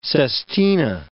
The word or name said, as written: sestina